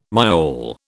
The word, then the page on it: myall: British English